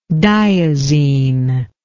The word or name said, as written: diazine